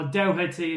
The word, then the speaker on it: Doherty: Pete Doherty, or Doherty as the Americans pronounce it.